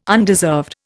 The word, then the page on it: undeserved: Indian